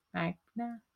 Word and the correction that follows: manga: themes. So they would put out their own manga. Sorry, manga.